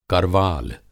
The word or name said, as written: करवाल